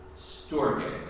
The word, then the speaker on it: storge: Second love is called storge. That's spelled S-T-O-R-G.